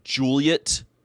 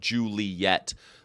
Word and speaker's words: Juliet: So I studied Shakespeare in college, and you know it's actually Romeo and Juliet. It's two. They're both two syllables. It's not. You hear people normally say, well, Romeo and Juliet, but it's not.